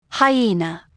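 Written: hyena

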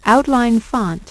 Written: outline font

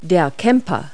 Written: camper

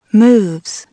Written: moves

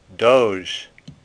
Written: Doge

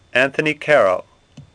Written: Caro

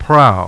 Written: prow

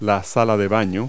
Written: bathroom

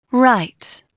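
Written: right

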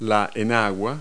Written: slip

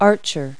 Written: archer

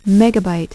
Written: megabyte

